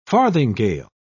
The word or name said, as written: farthingale